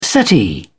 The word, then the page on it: settee: British English